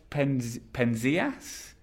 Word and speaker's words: Penzias: They were Walter Cohn from Austria, who won the Nobel Prize for Chemistry in 1998, and Arnos Penzias? Owen, would that be the right pronunciation? I mean, you've written it, so is that the right pronunciation? – Yeah, sure.